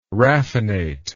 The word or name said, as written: raffinate